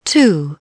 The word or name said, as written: two